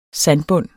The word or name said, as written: sandbund